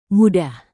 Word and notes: mudah: indonesian

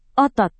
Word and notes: otot: Indonesian

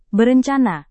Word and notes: berencana: Indonesian

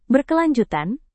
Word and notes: berkelanjutan: Indonesian

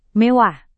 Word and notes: mewah: Indonesian

